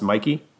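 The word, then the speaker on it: Michie: Is it Mikey or it's spelled Mitchy, but I think it's pronounced Mikey.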